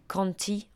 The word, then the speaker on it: Conte: En fait, je pense que ça se prononce Jack Conte en anglais. Ça s'écrit comme un conte en français.